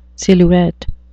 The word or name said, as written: silhouette